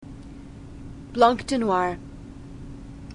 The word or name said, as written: blanc de noirs